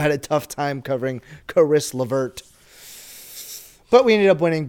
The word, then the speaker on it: Caris LeVert: However, I will say Caris LeVert, or however you say his name.